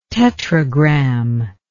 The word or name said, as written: tetragram